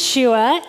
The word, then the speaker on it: Shua: My name is Shua. It's pronounced like Joshua, but without the J-O, so just Shua.